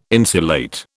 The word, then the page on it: insulate: British English